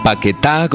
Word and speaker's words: Paquetá: Se llaman Paquetá, con acento en la A, y os lo recomendamos desde aquí.